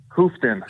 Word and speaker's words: Houghton: Houghton.